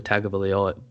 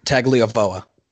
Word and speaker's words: Tagovailoa: and Alabama does not have Tua Tagovailoa. I butchered it. How do you do it? – Tagovailoa. – There we go.